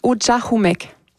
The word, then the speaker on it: Ujahumek: On le prononce Ujahumek.